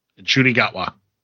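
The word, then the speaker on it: Ncuti Gatwa: Inshuti Gatwa.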